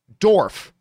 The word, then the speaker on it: Dorff: Stephen Dork, oh, I'm sorry, I mispronounced that, Dorf.